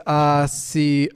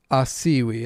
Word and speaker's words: Asiwi: Asiwi, it's Plains Cree. Asiwi is how to say it, apparently. Asiwi.